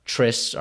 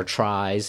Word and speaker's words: tris: And as we talked about in the Badugi episode, if no one has a four-card Badugi, then the best card trists or tries, however you might hear it pronounced, spelled T-R-I-S, which is the best three-card Badugi hand, would win half the pot if no one, like I said, has a four-card Badugi.